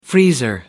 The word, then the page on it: freezer: American English